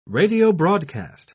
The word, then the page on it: radiobroadcast: British English